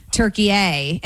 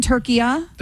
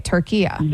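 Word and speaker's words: Türkiye: So you're saying honey, you're saying that in that language, it's always been Turkiya? – That's what he's saying, yes.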